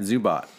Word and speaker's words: Zubot: Am I saying that right? Zubot. – Yeah. – And like you robot. The, Zubot. Okay.